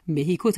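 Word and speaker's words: México: مکزیک را به شوخی مثل اسپانیایی‌ها مهیکو تلفظ کرده بود.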